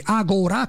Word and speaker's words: Agora: Agora con l'accento sulla A del professor Dorsi,